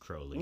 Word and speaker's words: Crowley: No, it's pronounced Crowley.